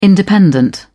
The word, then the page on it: independent: British English